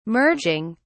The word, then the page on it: merging: British English